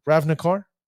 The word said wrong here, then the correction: Ravnica: Uh, Ravnik, uh, yeah, Ravnikar. No, yeah, Ravnica.